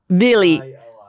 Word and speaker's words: Vili: Okay, the second one is Vili. – V-I-L-I.